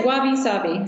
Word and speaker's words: wabi-sabi: Yes, wabi-sabi.